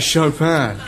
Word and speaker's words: Chopin: Chopin. – You have to say it like that, Chopin.